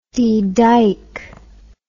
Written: dedyke

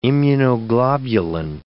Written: immunoglobulin

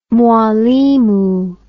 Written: mwalimu

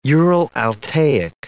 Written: Ural-Altaic

